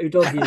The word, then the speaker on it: Udogie: But Udogie is how you pronounce it. Yeah, so let's stick with that. I know we've got some criticism. I was going to flag it up, but then I've just mispronounced it myself. So Udogie. I'm pretty sure it's Udogie.